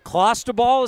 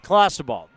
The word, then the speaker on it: clostebol: so clostebol is how you say it I, I'll take it all right clostebol this is the definition according to the athletic is a synthetic anabolic androgenic steroid.